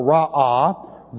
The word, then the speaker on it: ra'ah: The third line starts out with the hiphil participle ra'ah, those who do evil. Looks like this. R-A with a very long A. Ra'ah.